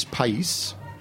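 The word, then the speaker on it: País: The grape is pais. – Spell it. – P-A-I-S. – Like country. Pais. – Like country, right.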